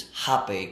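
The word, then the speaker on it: rapé: I get my rape, and then in Portuguese, it's rape, because they don't pronounce the R.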